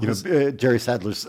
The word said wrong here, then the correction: Nadler: Sadler is the, am I getting his name right? Nadler. Nadler is the chair of the House Judiciary Committee, and he used to be like, at least twice the size as he is now.